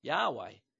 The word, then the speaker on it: Yahweh: Most people who study Hebrew say that this name should probably be pronounced Yahweh in preference to the Latinized word Jehovah.